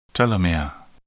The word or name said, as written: telomere